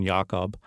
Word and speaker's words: Jakob: He published his first novel at age 26 in 1959, called Speculations About Jacob, or with the German pronunciation, Jakob, same year as The Tin Drum.